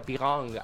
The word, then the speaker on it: Björn: Tenemos a Benny, tenemos a Björn, que yo digo Björn, pero es medio Pigong, algo así. – ¿Qué es J-O-R-N? – Es B-J o con diéresis R-N.